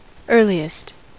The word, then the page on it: earliest: American English